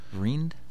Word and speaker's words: Vriend: And how do we say that? Vreend? – Vreend? Vreend? – V-R-I-E-N-D. – Yeah. Vreend.